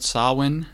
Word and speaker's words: Samhain: So we talked about last episode, Samhain is how it's pronounced, but it has different spellings, S-A-M-H-A-N or S-A-M-H-E-I-N, there are various spellings.